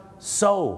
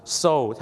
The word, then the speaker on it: sew: ซิลเหรอครับไม่ใช่ซิลครับผมซูโอ้ไม่ใช่นะฮะไม่ใช่ครับซูเนี่ยนะครับภาษาอังกฤษเนี่ยนะครับจะเป็นชื่อผู้หญิงหรือจะแปลว่าการฟ้องร้องคดีก็ได้ครับคำนี้ออกเสียงอะไรไหมครับโซถูกต้องแล้วครับ SEW ออกเสียงว่าโซครับ so